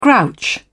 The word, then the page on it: grouch: British English